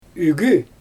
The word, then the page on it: ygy: Xyzyl